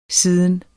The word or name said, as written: siden